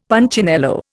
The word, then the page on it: Punchinello: Indian